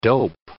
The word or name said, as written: dope